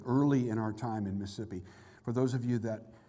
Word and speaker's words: Mississippi: And if you've lived there as long as we did, there's only three syllables in that word, so you pronounce it correctly, Mississippi.